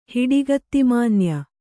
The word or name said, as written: ಹಿಡಿಗತ್ತಿಮಾನ್ಯ